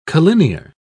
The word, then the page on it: collinear: British English